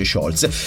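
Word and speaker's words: Sciolze: Se invece ce ne andiamo a Scholze, sì, non c'è l'accento quindi si dice Scholze, qua siamo in provincia di Torino, un altro prodotto autunnale vi aspetta, la zucca, 24esima sagra della zucca.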